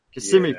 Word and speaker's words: Kissimmee: You're a cornerback prospect from Kissimmee, Florida. Am I saying it right? It's kind of a tongue twister. Kissimmee, yeah. Florida.